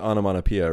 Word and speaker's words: onomatopoeia: Cause you pronounce it onomatopoeia, right? – Mm-hmm. – And then just add a T-I-C to the end to make it whatever.